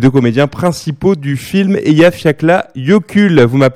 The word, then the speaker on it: Eyjafjallajökull: C'est le Eyjafjallajökull.